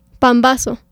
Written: Pambazo

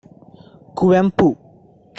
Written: Kuvempu

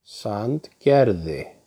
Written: Sandgerði

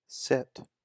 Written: Sit

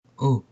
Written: Ô

